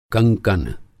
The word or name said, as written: कंकन